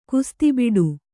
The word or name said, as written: ಕುಸ್ತಿಬಿಡು